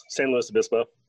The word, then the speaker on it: San Luis Obispo: How do you pronounce San Luis Obispo? Do you say Louis? Do you say Luis? Do you say Louis? – Louis, San Luis Obispo.